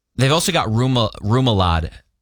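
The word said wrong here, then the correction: rémoulade: No, Ramulad.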